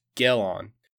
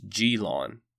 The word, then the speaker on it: Gelon: First, I have been calling the first Greek tyrant of Syracuse Gelon, when in fact I believe the pronunciation is Gelon.